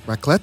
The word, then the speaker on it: raclette: It's a raclette. Is that how you pronounce it? – Yeah. Oh, yeah, wow. You said it right.